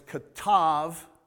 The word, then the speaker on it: katav: Now, if you've been around a little bit, you understand that bet can be pronounced B, hard B, or V. So when it's katav, those are the letters, kaf, tav, and bet.